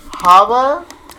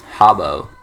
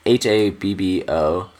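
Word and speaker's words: Habbo: Haba? – Habo.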